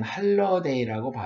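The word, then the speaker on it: holiday: 그런데 아무튼 홀리데이 그러잖아요. 미국에서도 홀리데이 인이라는 호텔 그 업체가 있기도 하고 그 홀리데이인데 홀리데이를 미국 사람들은 할러데이라고 발음을 합니다.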